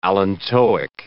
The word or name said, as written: allantoic